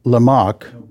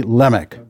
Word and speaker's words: Lamech: Well, I'm inclined to call it Lamech, but it's supposed to be Lamech. It's the emphasis on the first syllable, Lamech.